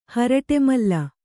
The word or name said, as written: ಹರಟೆಮಲ್ಲಿ